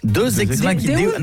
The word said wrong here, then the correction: Deus ex machina: Non, Deus ex machina. – Ah, parce que deux, j'aurais dit que c'était deux ex que t'appelles machine. Tu vois. – Non, ça se prononce Deus ex machina.